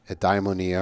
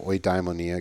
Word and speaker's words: eudaimonia: So when we think about shalom, we should also think about the Greek term eudaimonia or oidaimonia, it gets pronounced in different ways, which is often translated as happiness in English.